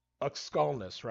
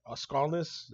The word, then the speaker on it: Aukskalnis: Now I want to talk first though about you, as far as you know, first off, it's Aukskalnis, right? Aukskalnis, is that how you pronounce it? – Yep, Aukskalnis, yep.